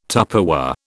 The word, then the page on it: Tupperware: British English